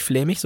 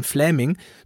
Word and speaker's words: Flaming: Das heißt natürlich nicht flämig, sondern Flaming.